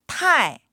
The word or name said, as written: tai4